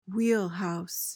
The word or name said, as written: wheelhouse